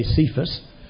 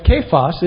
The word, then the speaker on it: Kephas: You are Simon, the mature son of Jonah, but you shall be called Kephas, is the Aramaic pronunciation. Usually we say Cephas, but Kephas is Aramaic.